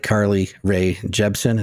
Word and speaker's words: Carly Rae Jepsen: Like Carly Rae Jepsen.